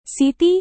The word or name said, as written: siti